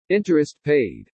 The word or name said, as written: interest paid